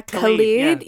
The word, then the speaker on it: Khalid: Yeah, Khalid.